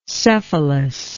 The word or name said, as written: cephalus